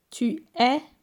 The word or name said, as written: Tu es